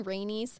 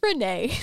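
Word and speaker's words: Renay: Fayetteville and Kill Devil Hills, North Carolina, where Renee's dream... Have I been saying Rainey's? I'm sorry. It's Renee.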